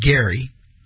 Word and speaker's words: Geary: G-E-A-R-Y, not, he probably pronounces it Gary.